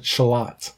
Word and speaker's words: Charlotte: Because you have towns named Chile that you pronounce Charlie, and towns that are spelt Charlotte, and you pronounce it Shalot.